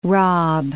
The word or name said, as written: rob